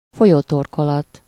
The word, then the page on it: embouchure: /ɑ̃.bu.ʃyʁ/